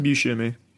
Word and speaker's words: Buscemi: Buscemi.